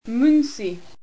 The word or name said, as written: Mynci